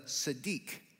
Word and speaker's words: tzaddik: In the Hebrew, it actually refers to a man who was a tzaddik, is how they would pronounce it.